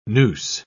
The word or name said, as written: noose